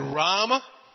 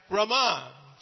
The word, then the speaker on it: Rama: We have Lord Rama. Long A first, last A short, and we have Rama. Well, first A is short, last A is long.